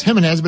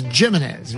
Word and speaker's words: Jimenez: spelled like it's Jimenez, but it's Jimenez, right?